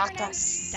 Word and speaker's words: Dacus: Lucy Dacus? Is that how you say Dacus. – Her name? Dacus. – That's how I say it.